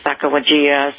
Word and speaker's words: Sacagawea: Her S-A-C-A-J-E-W-A, and it's, we pronounce it Sacagawea. Some people pronounce it a little bit differently, but that's the spelling of it.